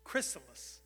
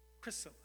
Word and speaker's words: chrysalis: God touches that caterpillar, and that caterpillar comes to a halt, spins a web or a cocoon, or how do you pronounce a word that starts with a C-H? Chrysalis. Chrysalis. And then after a period of time, he starts bursting through that chrysalis.